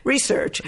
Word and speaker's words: research: and I needed to do my research. And why did I say research? – I don't know, but I liked it. You emphasized the wrong syllable. – I did indeed. Research.